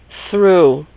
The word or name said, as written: threw